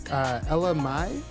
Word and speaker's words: Ella Mai: Ella Mai, I believe that's how you pronounce it. Oh. E-L-L-A-M-A-I. – Okay. I really thought it was like L-M-I. Like L, letter L-M-I. – L-M-F-A-O.